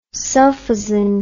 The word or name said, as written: sulfazin